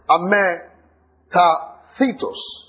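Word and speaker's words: Ametathetos (ἀμετάθετος): It's the word, then I'll pronounce it first. Ametathetos. A-M-E-T-A-T-H-E-T-O-S. A-M-E-T-A-T-H-E-T-O-S.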